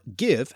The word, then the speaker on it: give: And though give did exist in Old English with that y sound as yefan and should have become yiv, the word also existed in a related Old Norse form in the north of England with a hard g, and therefore give has the pronunciation it does today.